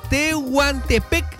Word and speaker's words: Tehuantepec: Tehuantepec, Oaxaca, México.